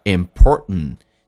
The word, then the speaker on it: important: 所以很多人在听这个 important 这个单词，不知道为什么，就是不知道这个单词是什么。那么是因为 t 跟 n 在一起的时候， t 是不发音，叫 important，气流阻塞 important，而且最后一个 t 也不发音，略音， time 跟 s 形成个零叫 time is，所以形成了我们听力的障碍，变成 how important time is。